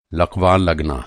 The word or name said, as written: लक़वा